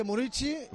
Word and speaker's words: Muriqi: Ah, Muriqi, se pronuncia Muriqi. – Sí, por lo visto en Kosovar se dice Murici, vale, y vale. Aquí estamos discutiendo a ver cómo le llamamos, si Muriki o Murici.